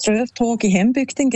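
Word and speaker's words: Strövtåg i hembygden: Ich finde, du hast die Aussprache super hergebracht. Das Lied heisst Ströftag in Hembygden, genau.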